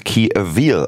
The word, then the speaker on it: Key Avil: Die Dame wird anscheinend Key Avil ausgesprochen, nicht Key Avil, wie ich es gerade von mir missraten aus meinem Mund kam.